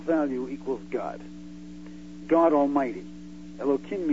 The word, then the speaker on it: Elohim: comes out to the same numerical value as Elohim. I'm not, you know, the end of that The third syllable of that is him. So there are three syllables, air, low, and the last syllable is him.